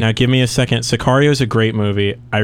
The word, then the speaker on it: Sicario: Is it, I don't know if I'm pronouncing this correctly, Sicario? – It is Sicario.